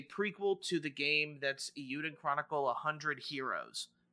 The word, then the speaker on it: Eiyuden: It's Eiyuden. Yeah, there's no L.